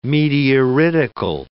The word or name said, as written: meteoritical